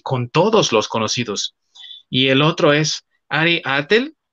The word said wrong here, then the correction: Ari Aster: espero haberlo pronunciado bien, no, perdón, Ari Aster, espero haberlo pronunciado bien porque aparte es sueco, que hizo la película de Hereditary, ¿no?